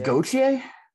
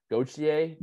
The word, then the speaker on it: Gauthier: Cutter Gauthier. – I think it's Gauthier. – Gauthier. – Yeah, somehow you put a C in there when you put a TH together.